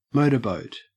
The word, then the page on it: motorboat: Australian English